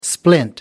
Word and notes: splint: American English

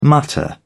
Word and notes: mutter: British English

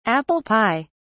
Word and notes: apple pie: British English

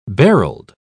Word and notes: barreled: British English

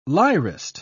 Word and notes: lyrist: British English

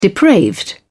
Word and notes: depraved: British English

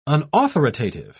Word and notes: unauthoritative: British English

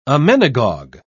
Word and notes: emmenagogue: British English

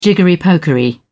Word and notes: jiggery-pokery: British English

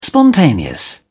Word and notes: spontaneous: British English

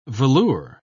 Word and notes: velure: British English

